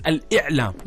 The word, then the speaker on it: Al-I'lam: Yes, it's Al-I'lam. Yeah. – Yeah, but sometimes when you're trying to say it fast. Actually, I said it right the first time. You just missed it. – What did you say? – I said Ilam. – It's not Ilam.